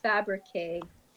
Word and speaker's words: Fabrique: How do you say it? What's the correct K French pronunciation? Fabrique. – I mean, Fabrique sounds very American. Go get your fabrique. – No, sorry, actually, really, there. That is fabrique.